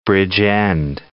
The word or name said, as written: Bridgend